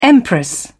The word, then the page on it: empress: British English